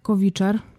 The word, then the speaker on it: Covither: że jest taka aplikacja jak Kowiczer. Pisze się to C-O-V-I-T-H-E-R.